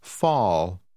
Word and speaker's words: fall: Fall. Fall. – Der Buchstabe A, gefolgt von einem Doppel-L, wird gewöhnlich wie ein A nach einem W ausgesprochen. Lesen Sie Nummer 16.